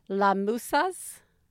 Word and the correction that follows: Las Musas: Las Musas, mm-hmm.